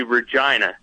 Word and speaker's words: Regina: Now, I make a point to say that because Kevin, our producer, actually took the time to type out Regina, Saskatchewan into our call screener here. So what's going on? – Hey, guys. It's actually Regina, just so you know. – Oh, that rhymes with...